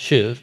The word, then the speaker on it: shuv: If you see it spelled, it looks like shove, like junior hires shoving or something. So, but it's actually pronounced shuv.